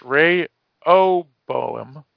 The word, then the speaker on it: Rehoboam: It's pronounced Rehoboam.